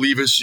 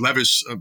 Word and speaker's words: Levis: Stroud, Bryce Young, Will Levis, you know, the top quarterbacks, or Levis, forgive me if I mispronounce his last name.